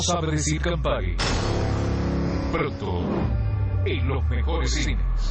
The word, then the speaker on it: Campari: Sí, traenos Campari. Campari. – No sabe pronunciar Campari. Campari. – Sí, ¿qué le sirvo? – ¿Me das un camperi? – ¿Un qué? – Un campana. – ¿Perdón? – Campari.